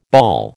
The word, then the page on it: bawl: American English